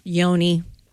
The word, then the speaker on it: Yoni: And this talks about, do you pronounce it Yoni or Yanni? – Yoni. – Yanni's the pianist, right? – Yeah.